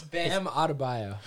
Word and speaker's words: Bam Adebayo: It's Bam Adebayo. – Look at him. He said it right.